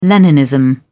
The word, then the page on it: leninism: British English